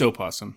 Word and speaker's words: opossum: I think you pronounce it just possum, right? – It's opossum.